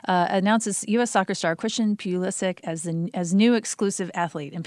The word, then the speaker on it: Pulisic: I always say it wrong. Pulisic. He's an 18-year-old soccer player.